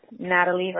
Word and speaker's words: Natalie: Just the normal spelling, Natalie Rodriguez.